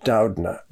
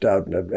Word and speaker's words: Doudna: Doudna. – Oops, sorry. – Even though it looks like a Doudna, but it actually is pronounced Doudna, at least by the Doudnas.